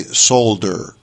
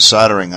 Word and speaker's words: solder: UK listeners, when they hear me say solder smoke, their pronunciation of solder is different from the American pronunciation. They pronounce the L in the UK. I think we've discussed this before. So they say solder, soldering. And, of course, in the States we just say soldering. It's a soldering iron.